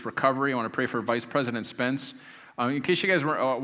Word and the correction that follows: Pence: Pence, what did I say? Like, when do we get a new vice president? Vice President Pence. I even have it written as Pence, but I don't know.